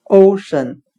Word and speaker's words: ocean: 我们可以用谐音法来记忆这个单词 ocean，它的发音很像汉语的欧深，o 为语气词，深是深浅的深。